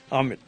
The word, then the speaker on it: Amit: Amit. Am I saying it right? – Absolutely. – How do you pronounce it? – Amit.